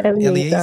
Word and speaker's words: Eliezer: Eliezer.